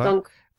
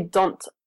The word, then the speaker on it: dont: Et attention à la prononciation, parce que toi, tu fais comme don't, avec le T. Ah. On ne prononce pas le T, on dit juste comme don. – Don. – Un don. Comme okay. D-O-N.